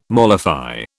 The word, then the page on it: mollify: British English